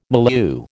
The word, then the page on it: milieu: American English